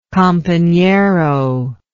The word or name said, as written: companero